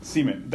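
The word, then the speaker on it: Siemian: Seaman. That's how we're pronouncing it.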